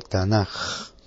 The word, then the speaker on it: Tanaj: Sin embargo, la última letra en hebreo tiene dos pronunciaciones, una pronunciación de K y una pronunciación de J. Por tanto, se toma la segunda pronunciación y el acróstico sale como Tanaj.